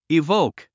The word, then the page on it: evoke: ivóuk; イボウク